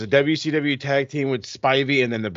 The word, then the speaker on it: Spivey: Spivey. – Spivey.